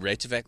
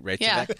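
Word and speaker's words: Reykjavík: Yeah, I mean, especially if you look around and look what Reykjavik like, looks like.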